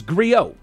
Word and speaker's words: griot: It's griot. G-R-I-O-T, griot. – The T is silent.